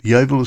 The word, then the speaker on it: Yeovil: Yeovil is spelled Y-E-O-V for Victor, A-L, Yeovil.